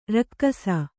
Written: ರಾಕಾಸಿ

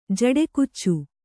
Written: ಜಡೆಕುಚ್ಚು

